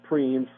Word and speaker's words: Primes: And Preams are it's spelled Primes, but it's pronounced Preams from the French.